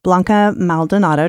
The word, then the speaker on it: Blanca Maldonado: Editor and owner of the Scout Guide Northwest Arkansas, and I am sitting here with Blanca Maldonado. Did I say that right? – Yes.